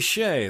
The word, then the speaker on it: Skjei: And before that, Minnesota pianist Amy Shea, that's spelled S-K-J-E-I, and her composition titled Autumn Moments.